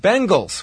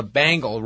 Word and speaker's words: Bengals: It's pronounced Bengals with an E. And thanks to your insisting that there's an animal called a bangle roaming the jungles, I now have eternal flame stuck in my head.